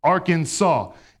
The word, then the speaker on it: Arkansas: In Arkansas, alligators may not be kept in bathtubs, and it is Arkansas must be pronounced Arkansas. And they get offended if you say Arkansas or Arkansas and add an S to it, which I'm like, why don't you guys just phonetically change it up a little bit and we could help everyone here.